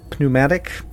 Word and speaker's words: pneumatic: Some like pneumatic, unsure what the word is. – Pneumatic. – The P is silent.